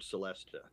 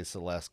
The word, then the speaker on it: Celesta: I think it's just because I like to say that the word Celesta. – I always say it wrong and just say Celeste because that's our cousin's name, but that's not the right.